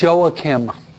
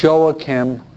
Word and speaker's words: Joachim: It's pronounced Joachim. Spanish called Joaquin. And Joachim is the way we say it in English.